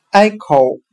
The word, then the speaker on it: echo: 我们可以用谐音法来记忆这个单词 echo，它的发音很像汉语的挨口，挨着的挨，洞口的口。